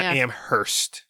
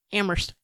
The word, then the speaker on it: Amherst: Was how you can tell you're from Western Mass is that you don't pronounce the H in Amherst. Oh, it's not Amherst. – Yeah, Amherst.